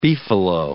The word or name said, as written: beefalo